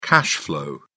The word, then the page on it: cash-flow: British English